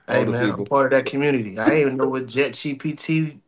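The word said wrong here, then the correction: ChatGPT: chat, not Jet GPT.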